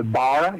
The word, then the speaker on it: Bara: They are called the Bara. It's spelled B-A-R-A, but it's pronounced Bar tribe.